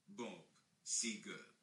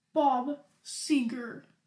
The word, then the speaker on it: Seger: So this is how you pronounce his name. – Bob Seger. – Bob Seger. Okay.